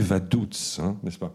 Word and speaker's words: Vaduz: Il dit Vaduz. Et c'est exactement la prononciation locale. Vous savez, c'est un allemand spécial, le liechtensteinien. Donc, il le dit à la liechtensteinienne. Vaduz.